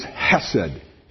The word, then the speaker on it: hesed: This is hesed.